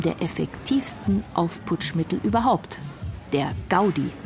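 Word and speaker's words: Gaudí: Betont man die Gaudi auf dem I, Gaudi, so ist sie männlich und ein katalanischer Architekt, der das Schräge dem Geraden und das Runde dem Eckigen vorzog.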